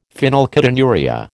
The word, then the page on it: phenylketonuria: American English